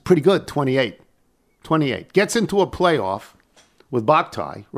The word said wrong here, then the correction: Bhatia: right? That's how it's pronounced? – Akshay Bhatia, Bhatia, okay.